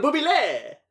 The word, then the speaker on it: Bublé: Michel Bublé!